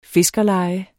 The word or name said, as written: fiskerleje